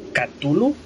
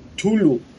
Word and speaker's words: Cthulhu: Es Sí, o sea, pero no cualquiera puede pronunciarlo y también este, lo podrían decir como Catulu... porque es la C con la T al principio como K y después Ulu, o lo podrían pronunciar, Tulu. He visto muchos medios que lo pronuncian como Tulu, pero pues este la racita, ¿no? Para la gente que lo conoce más comúnmente es el nombre de Cthulhu.